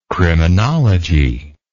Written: criminology

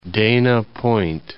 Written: Dana Point

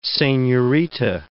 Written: senorita

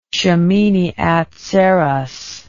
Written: shemini atzereth

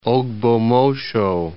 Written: Ogbomosho

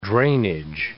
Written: drainage

